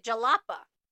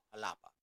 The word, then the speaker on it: Jalapa: However, us Americans, we're not pronouncing the J properly, so they were going to Jalapa. – So we were saying the J in English. However, this is a capital city in Mexico, so it's pronounced like an H, so Jalapa.